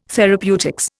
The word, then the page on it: therapeutics: Indian